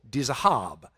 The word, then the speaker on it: Dizahab: and Dizahab," is actually how that's pronounced. The emphasis is on the last syllable, Dizahab. And by the way,